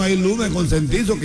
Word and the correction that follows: concientizo: Consentizo no se dice, se dice concientizo.